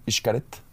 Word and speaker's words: Xcaret: Por eso, ¿ubicas el, el hotel Xcaret? Sí, claro. Se escribe con X. Hay gente que le dice Xcaret.